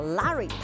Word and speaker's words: Larry: 但是呢，这一位赖瑞啊，那英国发音叫 Larry，他在这个唐宁街十号，那就待了很唐宁府十号待了好久啊，见证了风云变幻。